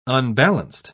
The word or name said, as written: unbalanced